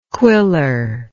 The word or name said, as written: quiller